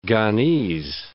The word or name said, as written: Ghanese